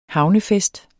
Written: havnefest

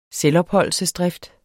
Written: selvopholdelsesdrift

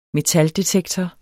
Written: metaldetektor

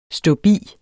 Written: ståbi

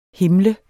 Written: HIMLE

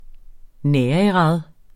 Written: nærigrad

